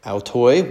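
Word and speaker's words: Altoi (αὐτοῖς): Altoi, well done. Okay, so good pronunciation.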